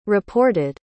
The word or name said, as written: reported